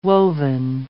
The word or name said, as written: woven